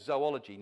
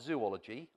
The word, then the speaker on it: zoology: And I chose a subject there called zoology, not zoology. I know people want to pronounce it like that, but it would need three O's.